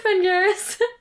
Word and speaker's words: Phengaris: It's spelled P-H, so it could be Phengaris, but probably Phen. – It's probably Phengaris.